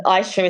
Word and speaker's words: Ishea: One is my name, Ishea Muhammad, and Ishea is spelled I, S for sugar, H, E for echo, A for alpha.